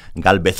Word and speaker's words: Gálvez: Bueno, recuérdanos un poquito sobre Gálvez. – Bueno, pues Gálvez, como les digo yo a los niños, porque es de España y ahí pronunciamos la Z, Gálvez, pues Gálvez era el gobernador.